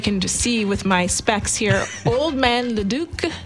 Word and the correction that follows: Ledecky: Close. – Leduc. – Ledecky. – Oh, Ledecky.